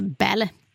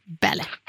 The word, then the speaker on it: Balle: Ja, och Solveig heter, det stavas B-A-L-L-E, men uttalas Bälle. Bälle.